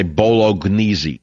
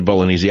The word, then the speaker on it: bolognese: The silent G. People say bolognese.